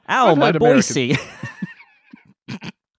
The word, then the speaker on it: Boise: It's got this wonderful kind of rubberiness to the way you say the S in Boise. – Yeah, it is evocative to me of the bouncing ball used in sing along cartoons. – Yes, it's got a shuffle feel to it, right? Boise, Idaho. – You know what's quite interesting is the Wikipedia pronunciation has it with a non sibilant S. – How does that sound? – No, a sibilant S.